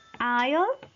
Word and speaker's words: aisle: Y silent S. Aisle, aisle, pasillo.